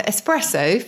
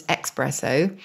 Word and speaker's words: espresso: So espresso, famously mispronounced as expresso. I think because people think it comes to you quickly or it comes out of the machine quickly.